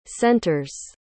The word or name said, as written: centers